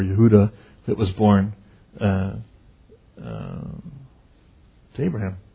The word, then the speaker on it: Yehuda: In English, we pronounce it Judah, but in Hebrew, it's Yehuda. There's no J sound, it's a Y.